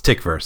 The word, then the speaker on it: Tykwer: Tom Tykwer, which is it Tykwer? Is it Tykwer? And eventually I heard somebody actually say it in an extra on a DVD, and it's Tykwer. So there you go. Yeah, he's German. I think that's a German pronunciation.